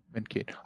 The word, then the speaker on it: Mankato: Mankato.